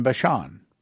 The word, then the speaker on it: Bashan: And he shall feed on Carmel and Bashan. By the way, that's how you pronounce it. I've been in Israel. I've heard how they pronounce those words.